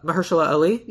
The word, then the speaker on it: Mahershala Ali: I cast, and I'm hopefully going to pronounce his name correctly, Mahershala Ali. – Mahershala Ali? – Yeah.